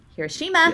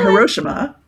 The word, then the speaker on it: Hiroshima: Hiroshima. Yeah. I got that. – Or Hiroshima. – Is it Hiroshima? Is it really? – Yes, it really is. – I said it wrong.